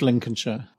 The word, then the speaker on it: Lincolnshire: Yeah, it's northeast Lincolnshire.